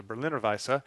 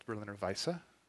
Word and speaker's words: Berliner Weisse: And we've got here in front of us a Berliner Weisse. Many Americans say Berliner Weiss. Actually, the E is voice, so it's Berliner Weisse.